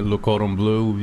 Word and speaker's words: Le Cordon Bleu: Or you could put a little flair in it, Le Cordon Bleu.